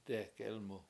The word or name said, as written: dE7ek Elmu